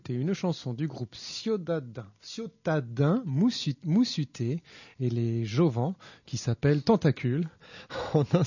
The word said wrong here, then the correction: Jovens: J'ai pas dû bien prononcer là, non ? – C'est les Jovens.